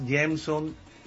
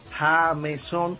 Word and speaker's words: Jameson: El nombre que me dio mi madre realmente se pronuncia en inglés, Jameson Ramírez, escribe Jameson con J. Jameson.